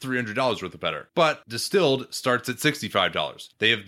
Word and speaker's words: DSTLD: Let's take a quick break here to talk about Distilled Premium Denim. Distilled, it's actually spelled D-S-T-L-D, but pronounced distilled, has revolutionized the fashion industry.